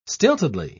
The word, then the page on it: stiltedly: British English